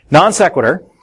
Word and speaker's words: non sequitur: Non sequitur, probably butchering that Latin, but it does not follow. – Non sequitur. Is how you pronounce the term, but you have the right term, yeah.